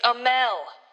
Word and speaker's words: Amell: It's Amell. Stephen and Robbie Amell.